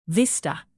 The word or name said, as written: vista